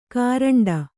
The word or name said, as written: ಕೋರಂಟಿ